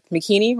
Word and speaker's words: Makini: And because my name is Swahili, so people always ask me, how do you pronounce it? I'm like, Makini rhymes with bikini.